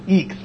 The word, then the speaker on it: IAX: Yeah, that's how the asterisk geeks pronounce it, Eeks, like E-E-K-S. I don't know. I always read it as I-A-X at first, and then I heard all the when I went to Astracon, they all said Eeks.